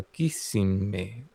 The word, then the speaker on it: Kissimmee: ¿Y cómo se pronuncia? Kissimmee.